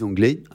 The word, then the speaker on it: anglais: But I put a dot on the end of the word anglais because I don't say it, yeah.